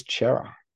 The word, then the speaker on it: Cerra: How do you say Sarah? I've never heard anyone else call him Sarah before. – The word is C-E-R-R-A. – But everyone says Chera. – I don't care. This is English, not make shit up week.